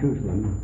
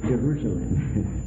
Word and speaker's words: Jerusalem: Brother Harding used to tell us now the name of that city is Jerusalem. It's not Jerusalem. It's Jerusalem. He made us pronounce it correctly.